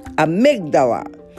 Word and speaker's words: amygdala: Amygdala.